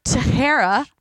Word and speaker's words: Teixeira: Mark Teixeira.